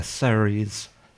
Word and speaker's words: Ceres: Yeah, Ceres.